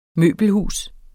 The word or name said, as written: møbelhus